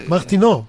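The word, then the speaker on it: Martineau: How do you say it in French? Martinu? Martinu? – Gary, you're spot on. Martinu.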